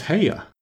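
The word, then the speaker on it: Haigha: His name is Haya." – He pronounced it so as to rhyme with mayor.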